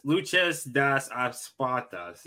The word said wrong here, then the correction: Lucha de Apuestas: Yeah, it's yes, Apuestas. Yeah. Lucha de Apuestas.